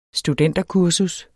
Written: studenterkursus

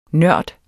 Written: nørd

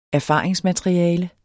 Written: erfaringsmateriale

